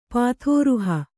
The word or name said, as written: ಪಾಥೋರುಹ